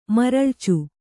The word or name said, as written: ಮರಳಿಚು